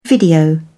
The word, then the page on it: video: British English